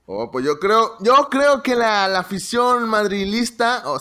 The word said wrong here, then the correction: madridista: ¿madrilista o madrileña? ¿Cómo se dice, David? – Madridista.